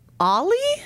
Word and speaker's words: Oli: So if you did not have the wonderful chance to read Barely Tamed Grizzly, the characters to catch you up are Ollie? – Sure. – Is that how you guys are pronouncing it? O-L-I.